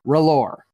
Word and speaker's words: R'hllor: And Stannis is like, R'hllor. That's how you say it.